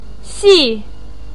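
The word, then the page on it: xi: tone 4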